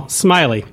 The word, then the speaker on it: Smillie: First of all, Smillie, that's how you pronounce it.